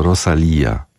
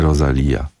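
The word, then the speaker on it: Rosalía: Panie redaktorze, jako absolwentka filologii hiszpańskiej mogę potwierdzić, że poprawna wymowa to Rosalia, nie Rosalia.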